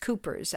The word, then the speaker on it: Cowper's: Actually, it's pronounced Cooper's.